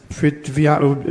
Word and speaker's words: Prithvyam: Ah, there's an extra syllable here. Prithvyam, it would sound better. Prithvyam Jayati Sarva. Let me check that one also. Prithvi is also a correct form.